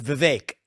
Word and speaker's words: Vivek: It's pretty close. It's Vivek, like cake.